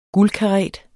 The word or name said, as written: guldkaret